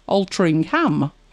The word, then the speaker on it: Altrincham: Spelt Altrincham. Pronounced Altrincham. But I hadn't noticed it.